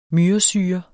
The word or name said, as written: myresyre